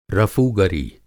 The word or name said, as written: रफ़ूगरी